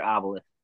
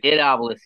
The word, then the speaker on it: Obelus: It's pronounced id as one word, obelus. – It Obelus? Okay. – It Obelus, yeah.